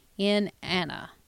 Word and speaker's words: Inanna: I'm not sure how to pronounce this. I-N-A-N-N-A. Inanna. – Inanna. – Inanna? – Yeah, that sounds about right.